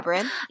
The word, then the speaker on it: Labyrinth: It was Pan's Lam- Lambreth. Am I pronouncing that right? – Pan's Lab- Labyrinth.